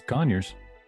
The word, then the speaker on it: Conyers: Atlanta Pizza in Euro in Conyers, Georgia. Did I say that right? Conyers, Georgia? – Yeah. – Oh, it's Conyers.